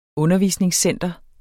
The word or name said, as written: undervisningscenter